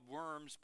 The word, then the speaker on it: Worms: It's spelled Worms, but you put a V in front of that.